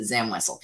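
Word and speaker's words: Zam Wesell: Literally every other guest I've had on this show has also pronounced it Zam Wessel, because that is indeed the correct pronunciation.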